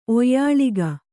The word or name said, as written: ಒಯ್ಯಾಳಿಗ